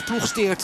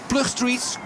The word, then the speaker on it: Ploegsteert: de plaats Ploegsteert lopen. En dus de Canadezen konden dat niet helemaal uitspreken, dus werden dat de Plug Streets.